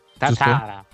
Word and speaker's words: Tatara: Invece bisogna pronunciare, l'accento va sulla prima, cioè, per cui è Tatara, cioè non, non, Tatara o qualcos'altro, no?